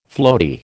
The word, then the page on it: floaty: American English